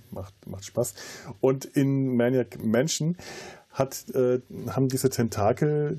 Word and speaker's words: Maniac Mansion: Also im Vorgängerspiel äh, Maniac, Man Ma Maniac, äh, ich kann es bis heute nicht Maniac Mansion aussprechen, weil es bei uns aus irgendeinem Grund, wir haben immer Manic Mansion dazu ja, gesagt.